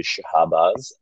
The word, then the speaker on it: Shabazz: It's a little bit of a tough one. It's a Persian name, and I mean, either one works fine. It's phonetically Shabazz, but you can call me Shabazz.